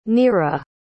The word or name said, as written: neera